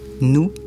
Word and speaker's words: noue: Là, nous, ça s'écrit « nous », N-O-U-E.